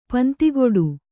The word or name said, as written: ಪಿಂದುಗಡೆ